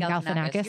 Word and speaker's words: Galifianakis: And it was kind of like Zach Galifianakis, I forget how to say his last name. – Galifianakis. You're right.